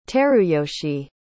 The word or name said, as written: teruyoshi